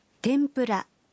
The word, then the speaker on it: tempura: Tempura is pronounced tempura.